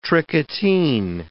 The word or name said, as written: tricotine